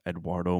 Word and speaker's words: Edoardo: It's spelled Edoardo, but I think it's Eduardo Molinari.